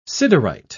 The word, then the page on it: siderite: British English